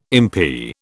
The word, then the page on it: impi: British English